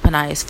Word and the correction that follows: Phineas: Phineas. I'm saying it wrong.